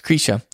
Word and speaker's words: Krisha: And it's spelled like Krisha, but it's actually pronounced Krisha. And it's spelled like what? – Krisha, K-R-I-S-H-A.